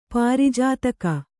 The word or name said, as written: ಪಾರಿಜಾತಕ